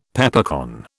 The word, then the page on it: Peppercorn: British English